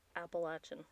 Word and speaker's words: Appalachian: I learned that you pronounce it Appalachian and not Appalachian. – Appalachian? – Yeah, like you're throwing an apple at you. Appalachian.